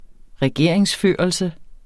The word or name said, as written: regeringsførelse